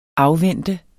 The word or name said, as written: afvente